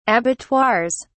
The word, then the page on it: abattoirs: British English